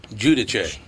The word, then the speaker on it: Giudice: Did you pronounce it the Italian way, Giudice? – Giudice. – Giudice.